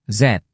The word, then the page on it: z: Indonesian